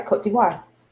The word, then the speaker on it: Côte d'Ivoire: She is from, and I guess it makes sense because she's from, see, I don't know how to pronounce that. Côte d'Ivoire? – Oh, yeah, that's right. Côte d'Ivoire.